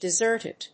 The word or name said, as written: deserted